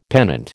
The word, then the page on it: pennant: American English